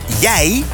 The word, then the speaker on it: jeg: Daar is het woord jij... Zo spreek je dat ook uit. Je spelt het J-E-G.